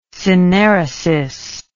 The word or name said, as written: synaeresis